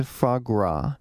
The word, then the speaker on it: foie gras: We are talking to Chef Jeff Henderson, author of Cooked, From the Streets to the Stove, From Cocaine to Foie Gras.